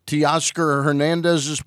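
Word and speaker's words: Teoscar Hernández: Teoscar Hernandez's play. Did I pronounce that right? – Yes, you did.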